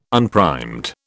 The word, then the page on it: unprimed: British English